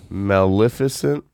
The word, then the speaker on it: Maleficent: Maleficent.